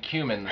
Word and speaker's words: cumin: I think you meant cumin, the spice. Cumin. We just mispronounced that.